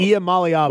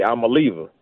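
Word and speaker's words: Iamaleava: Iamaliava. – But, okay, yeah. If you actually look at it, it say I'm a lever.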